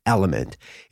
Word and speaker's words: LMNT: It's spelled L-M-N-T, Element.